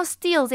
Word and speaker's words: steal: 那看到 steal 这一个字啊，它就是窃取的原型 steal， EA 呢是发长音 E 的音，那它这个字呢发音跟钢铁 steel 是一模一样的。我看到有些电影像什么 Real Steel 或者是 Man of Steel， Real Steel 就是钢铁擂台，就是 Hugh Jackman 演的那一部， I love it so much personally。